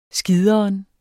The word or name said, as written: skideren